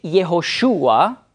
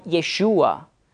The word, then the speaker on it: Jehoshua: Jesus ist die griechische Aussprache, also nicht Übersetzung, Aussprache von Hebräisch Jehoshua oder Kurzform Yeshua.